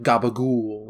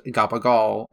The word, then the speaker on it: gabagool: So you've probably heard of, like, you know, like The Sopranos, like gabagool, like Italian-American pronunciation of the meat capicola. – Okay. – My family and our Italian heritage, a lot of those words just get horribly mispronounced. So I've always I've grown up with it being pronounced gabagal, and every person I've been to, like, down here knows what I'm fucking talking about.